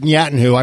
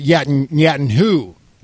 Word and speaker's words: Netanyahu: He said, I didn't pronounce it Netanyahu. I pronounced it Netanyahu. Like with emphasis on the who.